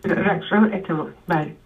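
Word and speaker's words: Rakhshan Banietemad: I wonder if you could speak about the relationship you had with the directors of these two films, because The May Lady, I don't know how to pronounce her name, Rakshanbani Etemad? – Etemad. Yes, Rakshan Etemad.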